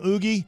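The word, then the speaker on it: Ugueth: Well, I used to say it's Oogrith. Is that how you say it right? – Oogith. – But a lot of people would call him Oogie.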